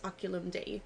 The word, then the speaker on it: Oculum Dei: At least my Latin teacher would have said it's Oculum Dei.